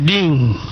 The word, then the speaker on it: डींग: नहीं ये डींग डींग ग नहीं है इसमें असल में कहने के लिए ग उच्चारण करते हैं मगर डींग वो हुआ है डींग